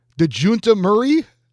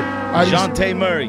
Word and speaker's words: Dejounte Murray: And I figured I wouldn't look up how to say this for possible comedic purposes, but I have never heard of DeJounte Murray. – Well, that's not how you say it. He's the point guard for the Spurs. He's pretty good. – DeJounte Murray.